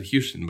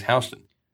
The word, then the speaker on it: Houston: Interesting how they say it's Houston there, you know? – Yes. – I think a lot of people would pronounce that Houston, but it is Houston.